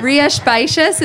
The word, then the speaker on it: Rías Baixas: Rías Baixas, it's spelled R I A S B A I X A S.